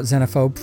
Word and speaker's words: Xenophobe: Well, I know that in America it's generally pronounced Xenophobe, with the long Z. But in the rest of the English-speaking world, and most notably within the UK and its former colonies, such as Australia, India, and Canada, it's generally pronounced Xenophobe, with a short Z, or Z. Now, I'm an American through and through, but I actually prefer the British pronunciation, Xenophobe, just because Z sounds so horribly horizontal to my musically trained ear. – Xenophobe is the correct pronunciation, so bully for you.